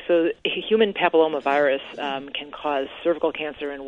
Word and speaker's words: papillomavirus: I've noticed a lot of my friends and acquaintances on Facebook are posting something about this HPV vaccination, which is it human papilloma? I don't even know how to say it. What's HPV? – Human papillomavirus. – I was close.